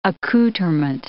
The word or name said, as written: accoutrement